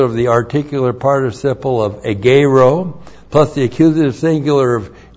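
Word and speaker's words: egeiro (ἐγείρω): E-G-E-I-R, long O, egeiro.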